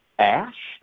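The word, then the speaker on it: Æ: But just the first part of his name, X-A-E, A-E, which he says is ash. They say that's A-E, you say it, that you pronounce it ash.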